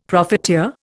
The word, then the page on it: profiteer: Indian